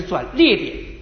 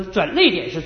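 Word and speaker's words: 转捩点: 你如果读范文澜的《中国通史》，你就发现他用的不是转折点，是转裂点。那转裂点没念好，就念成了转泪点。说转泪点是错别字。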